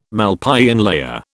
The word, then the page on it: Malpighian layer: British English